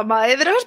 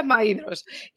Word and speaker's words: Maedhros: Hasta, hace tres minutos yo le llamaba Maedros, pero no. ya ha llegado para decir, por favor, pronunciadlo bien, se pronuncia Maidros.